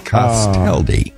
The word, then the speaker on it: Castaldi: And Carmen Castalda. How do you pronounce that? Castaldi. – Yeah, Castaldi. – Castaldi is on drums.